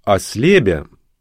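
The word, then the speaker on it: Ослябя: Слово «ослебя» с ударением на последнем слоге склонялось как «осля», «щеня», «теля», «ослябете» и так далее.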